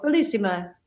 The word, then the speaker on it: Bellissima: So Bellissima, I'm saying that right? Yeah. Right? Yes. – Yes. Is Bellissima.